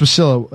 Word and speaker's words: Wasilla: Bristol Palin's ex-fiance recently announced plans to run for mayor of, I don't know how to say this city, Wasilla? – Yeah. Alaska? It's Wasilla. It's Wasilla for sure, yeah.